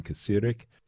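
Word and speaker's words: Kacirek: It's a duo consisting of German electronic experimental drummer Sven Kasirek, that's K-A-C-I-R-E-K, Sven Kasirek,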